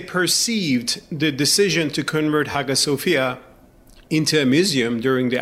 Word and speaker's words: Hagia Sophia: We all say Hagia Sophia, but in Turkish, you'd say Hagia Sophia, right? – In Turkish, it's called Hagia Sophia, which is Turkified version of the original name.